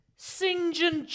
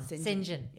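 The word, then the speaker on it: St John: The driver, Singin' Jock Horseful. – Yeah. Yes. – And it's really funny because I think that is how you say that name. Saint John. Singin'.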